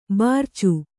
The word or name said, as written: ಭರ್ಚಿ